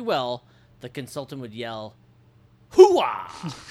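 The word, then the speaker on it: Hooah: is an actual United States Army battle cry, although he is saying it wrong. He places far too much of a W sound on the second syllable. The real version is closer to "Hoo-ah," not "Hoo-ah."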